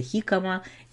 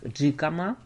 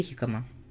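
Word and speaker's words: jícama: У нас называется хикама, пишется через J, как джикама, но произносится хикама.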